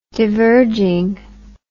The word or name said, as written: divergingly